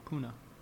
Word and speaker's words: Pune: P-U-N-E? – Pune, is that how you pronounce the name of the city? – On Wikipedia, it says Pune. – Oh, okay. Never mind. Pune. – But yes, P-U-N-E.